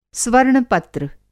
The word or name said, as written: स्वर्ण-पत्र